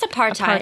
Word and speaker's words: apartheid: I know that we're not going to get into a lot of them today, but I did start reading this medical apartheid book. Am I even pronouncing that right? I'm, like, terrible at pronouncing things. – I think it's apartheid. – Apartheid, see? – I think you, it's a hard T.